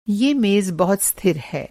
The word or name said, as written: स्थिर